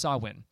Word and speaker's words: Samhain: It's called Samhain, and it's spelt Samhain. So you probably, if you've done any research, it's pronounced Samhain.